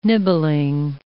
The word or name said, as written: nibbling